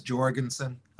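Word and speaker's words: Jourgensen: I think Jorgensen. is that what you say, or Jorgensen? – I think he pronounces it. I think he says Jorgensen.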